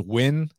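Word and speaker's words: Nguyen: And y'all let me know if Van is how you pronounce the last name, because like with some of these names, like it can be spelled Naguyan, but it actually pronounces Win.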